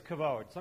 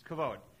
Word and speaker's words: kavod: The Hebrew word for glory is kavod. Some people try to pronounce it kabod. That's not correct. It's kavod.